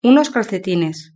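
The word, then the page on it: unos calcetines: Spanish (Spain)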